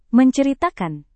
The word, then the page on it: menceritakan: Indonesian